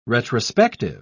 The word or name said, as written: retrospective